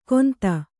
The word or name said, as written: ಕೊಂತ